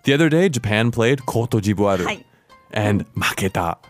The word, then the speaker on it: Côte d'Ivoire: So in French, it's Côte d'Ivoire. – Côte d'Ivoire.